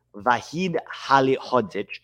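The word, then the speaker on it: Vahid Halilhodžić: Walid Halihadzovic. Let me get this right because I don't want to mispronounce names. His name is Vahid Halihadzic.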